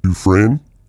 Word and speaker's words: Dufresne: It's actually Dufresne, like Andy Dufresne from the Shawshank Redemption.